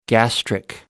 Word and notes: gastric: American English

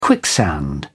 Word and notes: quicksand: British English

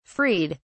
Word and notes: freed: British English

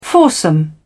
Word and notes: foursome: British English

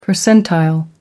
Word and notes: percentile: American English

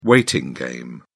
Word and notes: waiting game: British English

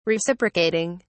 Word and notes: reciprocating: British English

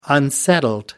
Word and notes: unsettled: American English

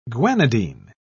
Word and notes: guanidine: British English

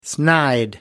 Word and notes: snide: American English

